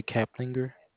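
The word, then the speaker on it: Caplinger: Letha Kaplinger? – That's correct.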